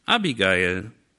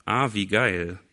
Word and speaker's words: Abigail: Der Mann hieß Nabal, seine Frau aber hieß Abigail oder Avigail. Tatsächlich wird es so ausgesprochen. Ähm, in Deutschland ist das ein bisschen verfänglich. Abigail, da fahren wir, glaube ich, besser mit.